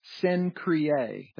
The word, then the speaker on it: Cenchreae: or servant of the church in Cenchreae. That's the correct pronunciation of that word. Cenchreae, or Cenchreae, some pronounce it.